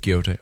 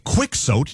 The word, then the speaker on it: Quixote: You do the go with Quixote. Okay. – Good. I mean, you don't go quick soat, do you? – There's no other way to go but Quixote.